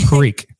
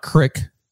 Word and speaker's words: creek: Frank asks, "It's how do you say creek? Exactly how it's spelled. Creek. – Creek. – It's not crick.